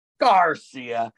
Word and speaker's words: Garcia: Garcia, because it's easy to yell. Three syllables, like America.